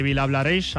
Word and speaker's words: Vilablareix: Desde Vilablareix,